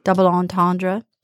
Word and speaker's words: double entendre: I want to remind everybody that Opera Moto is doing their double entendre. Did I say it right? – You did.